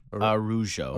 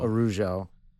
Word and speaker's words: Araújo: Is it Araújo? Araújo? – Viviane Araújo. – Araújo.